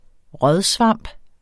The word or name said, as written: rådsvamp